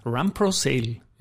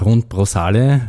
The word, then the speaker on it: runprosale: Stimmt Run Pro Sale überhaupt und ja, was ist die Idee? Es stimmt perfekt, äh, perfekt ausgesprochen. Aber es stimmt natürlich auch, dass da speziell zu Beginn, äh, wie ich gestartet habe, 2015, zu gewissen Abwandlungen gekommen ist. Mit äh, das Beste war äh, Rundprosale.